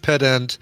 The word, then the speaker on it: pedant: So uh, Zoe says it's pronounced pedant, even even emphasis on both syllables.